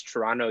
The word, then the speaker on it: Toronto: And Ricardo, I might just add that when you pronounce Toronto, you actually dropped the second T, like no, most people from Toronto do, which is which is yes, excellent.